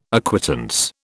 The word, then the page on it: acquittance: British English